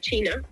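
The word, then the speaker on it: qi: Claro, claro, digamos que hay una palabra que se repite mucho en medicina china, que, se, que es el chi, el chi escrito con, con Q, porque en chino eh, la Q se pronuncia como una CH un poquito más fuerte,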